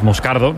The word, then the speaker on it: Moscardo: Entonces, sigo, como bien ha pronunciado Unai, es Moscardo,